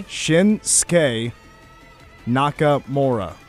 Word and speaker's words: Shinsuke Nakamura: Can you pronounce it phonetically for me, please? Slowly? – Shinsuke Nakamura. – Shinsuke Nakamura. Okay, that's much easier.